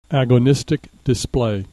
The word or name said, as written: agonistic display